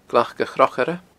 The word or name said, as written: glacachrochaire